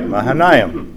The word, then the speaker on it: Mahanaim: So he named that place Mahanaim. Yes. – Very good. You did that right. Mahanaim, phonetically.